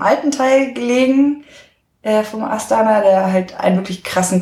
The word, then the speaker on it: Astana: Und wenn du jetzt so zurückdenkst an deine Zeit in Astana, wie spricht man das aus? Astana.